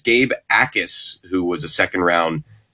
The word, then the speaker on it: Jacas: His last name is spelled J-A-C-A-S, but it's pronounced Akis. So silent J there.